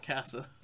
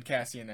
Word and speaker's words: Cassian: It's Cassian, not Cassa. – Sorry, I said Cassian and just kind of trailed off because I didn't want to say it wrong.